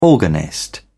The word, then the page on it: organist: British English